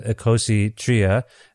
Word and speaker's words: Icositria: Now, just to clarify for people listening, however you pronounce the album name, let's go with Icositria. This is the Greek word for 23. Is that correct? – Exactly.